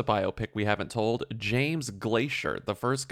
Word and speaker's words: Glaisher: He was obsessed with weather and his last name was Glacier. – But it's spelled G-L-A-I-S-H-E-R. – Okay. – It's like phonetically Glacier.